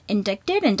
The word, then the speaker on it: indicted: And there was, like, a lynch mob before the suspects had been indicted. Is that how it's said? It's I-N-D-I-C-T-E-D. – Yeah. – Why is there a fucking T in there? – I don't know.